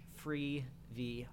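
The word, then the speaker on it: Freevee: I think they're dropping two a week, so go watch Sprung on Amazon Prime slash free V. I hate that name; it's so dumb. – But go Free watch it. It's funny. I'd recommend it. – it rhymes with TV.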